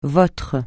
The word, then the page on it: votre: francais